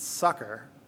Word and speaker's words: succor: And I checked the pronunciation this morning. It's succor, S-U-C-C-O-R, meaning assistance or a support in hard times.